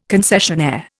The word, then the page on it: concessionaire: Indian